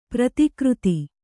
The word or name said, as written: ಪ್ರತಿಕೃತ